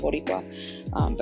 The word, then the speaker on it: Boricua: I'm going to say I'm 100% Boricua, better known as Puerto Rican.